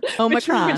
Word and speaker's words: Omicron: Well, it's just really bad timing because Omicron. How do we say it? – Omicron.